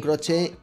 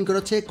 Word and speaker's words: Croce: I just have to ask you, how do you say your own name? It's spelled C-R-O-C-E. – Croce. – Croce. – Exactamente igual que lo está pronunciando Bang Bang.